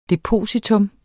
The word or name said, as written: depositum